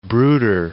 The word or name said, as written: brooder